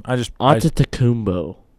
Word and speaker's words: Antetokounmpo: That's why I don't have How do you say his last name? – Antetokounmpo. – Okay, that's better than I did.